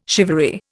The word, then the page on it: shivaree: Indian